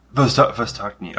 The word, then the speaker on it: Vostochny: But Vostochny Cosmodrome. – Vostochny.